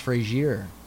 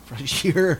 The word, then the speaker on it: Fraser: I also need you to be a Brendan Fraser. – Got it. – That's really how it's spelled. Fraser. That's not a misspell. – Oh, no. – But yeah. – It's pronounced Frazier. – Frazier.